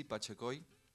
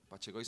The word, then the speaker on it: Pachecoy: Es Maxi Pachecoy. Pachecoy se escribe con una Y al final. Es Pacheco con Y. Apellido lituano deformado. Y nada, me encuentran como Maxi Pachecoy en todas las plataformas.